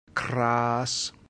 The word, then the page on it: cras: Latin